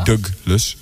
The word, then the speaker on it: Douglas: Ik hoor de hele tijd de Nederlandse artiesten roepen in interviews, onder meer Gerard Joling en Peter Douglas, zo moet je het uitspreken. – Ja. – Douglas.